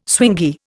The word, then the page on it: swingy: Indian